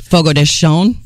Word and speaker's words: Fogo de Chão: Well, I call it Fogo de Chão, but it's actually pronounced... – Fogo de Chão.